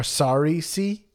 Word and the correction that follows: sarissa: Excuse me, a sarisa, a sarissa. Spelled like Marissa with an S at the beginning.